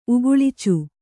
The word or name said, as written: ಉಗುಳ್ಚು